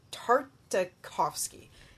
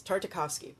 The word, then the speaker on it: Tartakovsky: It's an animated show by Genndy Tartakovsky. There, I got it. I had to think of all the syllables. Tartakovsky.